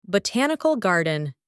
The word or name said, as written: botanical garden